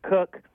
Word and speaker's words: Koch: Number two, Steve, this was a dispatch from the one son of liberty to another son of liberty, and that was Trent Cook. I believe it's spelled K-O-C-H. Trent Cook sent this dispatch to one Thomas Jefferson during his consternation on granting us the Second Amendment.